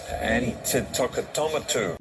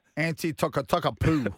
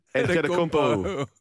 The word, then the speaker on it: Antetokounmpo: Basil, how do you say it, Basil? – Giannis Antetokounmpo. – Antetokounmpo. – No. – Spud, you did a better job than that. – Anti-toka-toka-poo. – Antetokounmpo. – Yeah, that's it.